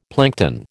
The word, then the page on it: plankton: American English